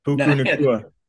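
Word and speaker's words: Puka Nacua: Puka Nakua.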